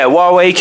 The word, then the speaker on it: Huawei: Yeah, Huawei came out. That's how you say it, by the way. Huawei, Huawei. I heard loads of people calling it Huawei.